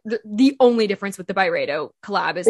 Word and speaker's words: Byredo: How do you say it? Byredo? – Byredo.